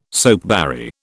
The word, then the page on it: soapberry: British English